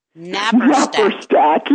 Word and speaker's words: Naparstek: And it's Belleruth Naparstek, yes.